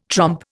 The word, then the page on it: trompe: Indian